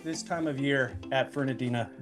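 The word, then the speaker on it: Fernandina: You are the pastor at Amelia Baptist Church in Fernadina Beach, Florida. See, I pronounced it perfectly. Did you notice that? – You did, your first time too. There was no correction.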